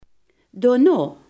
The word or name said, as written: daarna